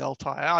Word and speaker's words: Altai: It was the Warrior of the, is it the Altai? – The Altai. I pronounce it Altai.